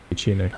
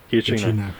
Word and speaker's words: Giacchino: Giacchino. – How to pronounce it, sir? Giacchino.